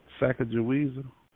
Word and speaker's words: Sacagawea: Sacagawea. – Yeah. Oh, well, I guess I was off by a, what do you call it, dialect? Or I don't know. What do you call that? – Sacagawea? – Yeah. – Yeah, it's S-A-C-A-G-A-W-E-A.